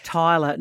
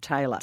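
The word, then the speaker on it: Tyla: That's Tyler, not Taylor. – T-Y-L-A. T-Y-L-A.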